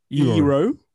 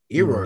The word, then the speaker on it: Eero: Tell me how to say this. E-E-R-O. – Ero. – Ero. – Is that Ero? – Ero. – Double E-R-O.